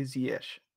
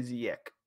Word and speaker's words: Ziyech: All the North Africans who I hear talk about him say Ziyech. They don't say Ziyech.